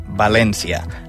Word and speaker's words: València: La versió en català o valencià s'haurà d'escriure amb accent tancat a la E. Així doncs, s'hauria de pronunciar València.